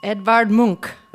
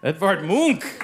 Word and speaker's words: Edvard Munch: Edvard Munch. – Edvard Munch! Extra credit for the pronunciation, yes.